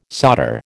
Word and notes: solder: American English

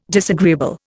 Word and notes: disagreeable: Indian

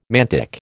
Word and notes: mantic: American English

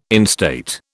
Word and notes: instate: British English

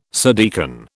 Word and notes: subdeacon: British English

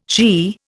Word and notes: she: Indian

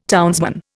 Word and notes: townsman: Indian

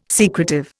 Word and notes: secretive: Indian

